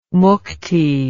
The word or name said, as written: mukti